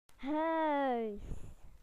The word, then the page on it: home: British English